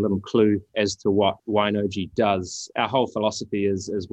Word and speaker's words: Wine-OJI: And I am pronouncing that correctly, right? – Yeah, you're pronouncing it correctly. Okay. Absolutely. – Excellent. – It is. So, thanks, Kim. Basically, Wine-OJI is,